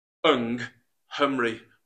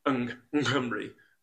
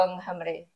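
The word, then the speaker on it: yng Nghymru: But I would, in my own head, I'm telling myself, Ung Humri, rather than Ung Nghymri. I'm trying to, so there's a little tip for people out there. – So the two NGs in Ung Humri, you don't say, pronounce both of them, they just run in together.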